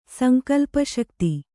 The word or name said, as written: ಸಂಕಲ್ಪಶಕ್ತಿ